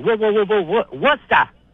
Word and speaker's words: Worcester: Worcester. Two syllables. Worcester. – That's what I said, Worcester. That's where the real people in Massachusetts live. – No, that's the British way of saying it.